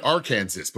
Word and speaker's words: Arkansas: Well, but yeah, anytime it comes to pronunciation, because we're in America, I'm going to, this fun one, pronounce K-A-N-S-A-S. – No. – It's Kansas. Pronounce A-R-K-A-N-S-A-S. It should be Arkansas, but we pronounced it Arkansas.